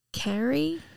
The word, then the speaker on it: Carey: It's Carrie. C-A-R-E-Y. – Carrie. I would say Carrie.